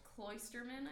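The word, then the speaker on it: Klosterman: Chuck Cloisterman, I think is how you say his name.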